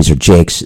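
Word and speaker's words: Jaques: Well, there's a question whether it's Jacques or Jakes. If it's Jakes, then it's a bad blue pun on the name of the character as well as the Elizabethan name for a toilet.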